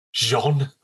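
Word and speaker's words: Gens: Xavier Jens, who's a French director. – Wouldn't that be like Xavier Jean? – Yeah, sorry. I absolutely butchered his name.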